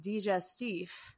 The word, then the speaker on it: digestif: I think your G is actually a little too hard. I think it's digestif.